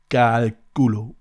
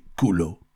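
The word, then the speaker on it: cálculo: My friend Pastora, she's from Concordia, and she explained me, I have to pronounce Calculo, which means calculation, calculieren, calculation, actually. And she said, you're not supposed to say culo.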